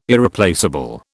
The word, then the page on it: Irreplaceable: British English